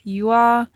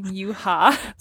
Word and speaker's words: Yua: At least you were able to say her name this time. – Literally. So this last time that we recorded, I was saying Yuha.